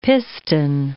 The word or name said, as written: piston